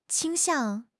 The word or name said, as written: 倾向